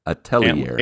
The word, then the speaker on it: Atelier: It's spelled A-T-E-L-I-E-R. Atelier. – Antler. – But what? Yeah, you're real close. It's a Christmas game. Atelier. Okay, the R is silent. It must be French or Italian.